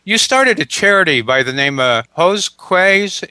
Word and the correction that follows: Gosques: Is that the right pronunciation? – Actually, it's Gosques.